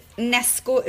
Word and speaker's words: Knesko: Nej det heter Nesco uttalar man det men det stavas Knesko. – Alltså ah. – K-N-E-S-K-O.